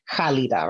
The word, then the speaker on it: Khalida: Khalida, right? – Yes.